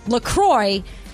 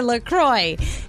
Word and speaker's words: La Croix: And they even say on their website, it's definitely pronounced La Croix. And if you ever get confused about it, just remember it rhymes with enjoy. So enjoy your La Croix.